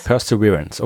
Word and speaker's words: Perseverance: Ich weiß es aber auch nur, weil mich jemand darauf aufmerksam gemacht hat, weißt du, irgendwie 8 Jahre in England und ich glaube, ich habe die ganze Zeit Perseverance gesagt und niemand hat mir jemals gesagt, dass das falsch ist.